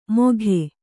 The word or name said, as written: ಮೋಘೆ